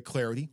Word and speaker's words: Clardy: That way you're not, you can constantly check my Twitter at Troy Clarity. Last name is spelled C-L-A-R-D-Y.